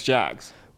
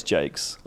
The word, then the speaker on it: Jacques: I always thought it was Alex Jacks. – Well, it's spelt like Jacques, isn't it? Like J-A-C-Q-U-E-S, I think. But apparently it's Jakes.